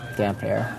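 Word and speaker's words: Damphair: How do you pronounce Aaron Greyjoy's title? Damp Fair. That's from Rob. – Damp Hair. – Yes.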